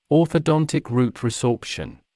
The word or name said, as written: orthodontic root resorption